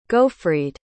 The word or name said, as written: gofried